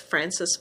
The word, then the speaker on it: Frances: And that's Frances spelled with F-R-A-N-C-E-S. My last name is Barry, B-A-R-R-Y.